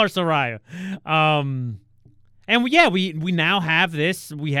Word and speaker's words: Saraya: She just wrote the pronunciation in a tweet, I think. It's Saraya is, I believe, how you say her.